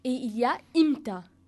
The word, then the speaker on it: imta: Imta, c'est plus simple. – Oui, imta. – Qui s'écrit de la manière I-M-T-A.